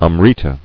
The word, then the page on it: Amrita: am·ri·ta